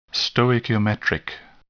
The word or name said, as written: stoichiometric